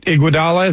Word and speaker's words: Iguodala: But Andre Iguodala, I'm probably going to butcher that. Iguodala, is that close? – That's exactly right.